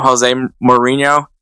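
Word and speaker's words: José Mourinho: I know a lot of people like to call him Jose Mourinho. It's Jose Mourinho. That's the proper pronunciation of Jose's name.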